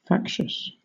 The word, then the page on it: factious: British English